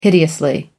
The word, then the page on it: hideously: American English